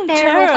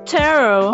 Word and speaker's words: tarot: Medyo, ano nga, walang T ako nakikita sa, ano, sa pag-pronounce. Pronounce. – Tarot pala. – Tarot.